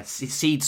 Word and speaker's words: Ceed: Yeah, Ceed spelled C-double-E.